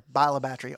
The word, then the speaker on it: Bayou La Batre: we pronounce it there. Bayou La Batre, all one word.